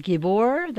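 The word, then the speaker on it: Gibor (גיבור): Also, to pronounce that word, it is Gibor. That's what mighty men is in Hebrew, Gibor.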